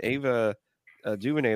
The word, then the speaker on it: DuVernay: And if I'm not mistaken, Ava DuVernay, the one who directed, I'm probably saying her name wrong and I suck. And I think you're saying it right.